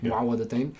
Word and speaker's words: Mu'awwadhatayn: Mu'awwadhatayn.